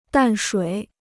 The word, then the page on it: 淡水: dàn shuǐ